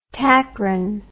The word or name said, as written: tacrin